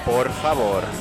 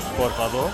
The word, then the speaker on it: por favor: It's very important, the R. Por favor. Por favor. I mean, you have to over say it, but it's roll the R's, babe.